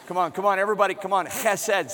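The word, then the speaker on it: hesed (חסד): But actually, the way that they pronounce it, we don't have this consonant sound in our language, but it's kind of fun to say. It sounds like you're clearing your throat. It's hesed, hesed.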